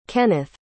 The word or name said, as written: kenneth